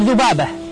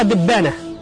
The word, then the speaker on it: ذبابة: من أشهر أيضا ما عرفت به اللهجة المصرية أيضا القلب في بعض حروفها، فمثلا الذال يقلبونها إلى دال مثل ذبابة، المصريون ينطقونها دبانة، فأصلها معروف لأن عندهم قاعدة قلب الذال إلى دال.